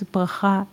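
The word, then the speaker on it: bracha (בְּרָכָה): But with a different nikud or pronunciation, the pool, brecha, becomes bracha, blessing.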